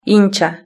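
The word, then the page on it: hincha: Spain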